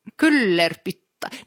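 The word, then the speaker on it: Kyllerpyttaa: Det stavas ju med liksom finska uttalsregler med y. – Ja, ja. Så det blir u. K-Y-L-L-E-R-P-Y-T-T-A-A. Kyllerputta.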